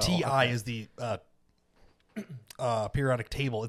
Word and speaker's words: Ti: So you're supposed to pronounce it TI as in yeah. Titanium.